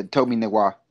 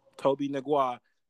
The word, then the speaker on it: Tobe Nwigwe: Uh, this song is uh, by Tobe Nwigwe. – Yeah, Tobe Nwigwe. – Tobe Nwigwe.